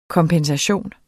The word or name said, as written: kompensation